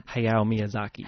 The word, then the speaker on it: Hayao Miyazaki: Hayao Miyazaki.